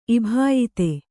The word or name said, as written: ಇಭಾಯಿತೆ